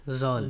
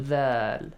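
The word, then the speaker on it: ذال: زَال. – ذَال.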